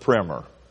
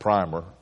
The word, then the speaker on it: primer: The second best selling book in the New England colonies was called the New England Primer. I would probably always pronounce it primer, but it's actually called the primer.